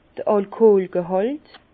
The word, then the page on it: d'r Alkoolgehàlt: Bas-Rhin; Herrlisheim